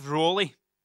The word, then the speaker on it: Rowley: Or Jeff Rowley. That's how you pronounce it.